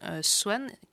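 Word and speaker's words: Xuân: Alors Daphné Swann, c'est un nom de scène, et Swann en fait c'est l'écriture occidentalisée de mon deuxième prénom, Swann, qui s'écrit normalement X-U-A-N, c'est un prénom d'origine vietnamienne qui signifie printemps. Et dans ce Swann, il y a ce fameux accent circonflexe sur le A.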